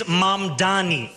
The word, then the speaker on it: Mamdani: And furthermore, the name is Mamdani. M-A-M-D-A-N-I. You should learn how to say it.